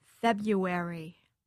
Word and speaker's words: February: But the truth is there have been studies of this done that show that most people do not pronounce the first R. Most people say February.